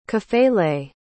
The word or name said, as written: kafele